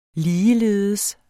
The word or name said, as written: ligeledes